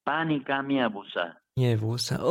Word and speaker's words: pani câ meusa: Sì, sì, sì, Mi ripeti come si pronuncia esattamente il pani? Panica miavusa.